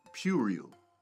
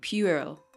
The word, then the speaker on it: puerile: It's spelled P-U-E-R-I-L-E. Think of the P-U being pew. – Puerile. Wait, what is it? – Puerile.